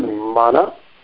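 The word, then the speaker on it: māna: Uh, the word, uh, the word mana, with this long a here, mana.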